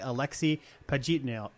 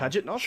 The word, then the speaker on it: Pajitnov: So, Tetris was created in June of 1984 by Alexei Pajitnov. I'm still saying that wrong. – Pajitnov? – Sure. – Pajitnov. Yeah, I practiced this and I still said it wrong.